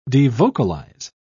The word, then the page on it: devocalize: British English